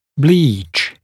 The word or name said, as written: bleach